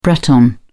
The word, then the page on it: breton: British English